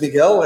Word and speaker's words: Miguel: My name is Miguel, as you mentioned, and SheerME is a marketplace for people to find whatever they need to take better care of themselves.